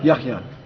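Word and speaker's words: Yahya (يحيى): Yahya.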